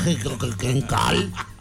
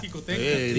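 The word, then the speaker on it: Xicoténcatl: Dice un señor que sale ahí en el radio. No. Calle Jicotencal. – Es Jicotencatl. – Díganle cómo se dice Jicotencatl.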